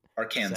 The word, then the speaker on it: Arkansan: Is that how you pronounce demonym of Arkansas? – Arkansan? – I think that's probably accurate. – Arkansan, sadly.